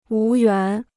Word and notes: 无缘: wú yuán

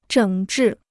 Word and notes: 整治: zhěng zhì

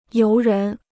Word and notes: 游人: yóu rén

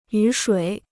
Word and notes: 雨水: yǔ shuǐ

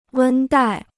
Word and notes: 温带: wēn dài

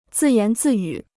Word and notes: 自言自语: zì yán zì yǔ

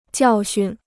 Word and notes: 教训: jiào xun

